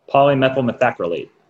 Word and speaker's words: Polymethylmethacrylate: Polymethylmethacrylate.